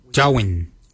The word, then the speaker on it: djowiny: Kart is spelled G-A-A-D, kart. The next word is hair. For hair, we say jawin. Jawin. Jawin is spelled D-J-O-W-I-N-Y.